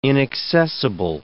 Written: inaccessible

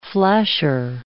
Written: flasher

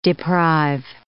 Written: deprive

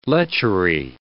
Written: lechery

